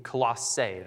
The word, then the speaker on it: Colossae: it was written to the church in Colossae.